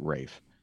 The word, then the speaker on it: Fiennes: I don't know who Ralph Fiennes is. – It looks like Ralph, but he pronounces it Rafe.